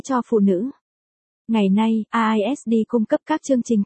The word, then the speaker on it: RISD: The Rhode Island School of Design, RISD, phát âm là RISD, là một trường nghệ thuật và thiết kế tư nhân ở Providence, Rhode Island.